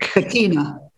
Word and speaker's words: Shekinah: You said Shinnik? – Shekinah. The Shekinah of Shekinah.